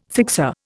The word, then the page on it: fixer: Indian